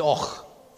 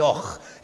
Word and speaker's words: Avoch: If you go to a little place on the Black Isle, it's spelt A-V-O-C-H, and that's just pronounced Och. Now, all the locals just call it Och. If you pronounce the first two letters of the word, they will wonder what you're talking about.